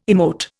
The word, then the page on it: emote: Indian